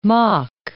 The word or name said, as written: Mach